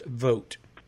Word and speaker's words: Vogt: I think Jennifer has chimed in to say that V-O-G-T is pronounced vote.